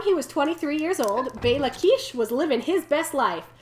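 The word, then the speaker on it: Béla Kiss: Because we're about to talk about Bela Kish, which is spelled B-E-L-A-K-I-S-S, and I'd like to thank Google Translate for pronouncing the Slovak and Hungarian names for me.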